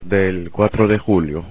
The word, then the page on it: del cuatro de julio: spanish